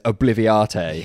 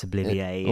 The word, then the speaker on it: obliviate: He says, obliviate. – Yeah, I'm like, no, we all know it's obliviate.